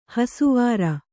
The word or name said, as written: ಹೊಸವರು